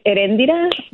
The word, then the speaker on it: Eréndira: It's Eréndira, but I go by Ere.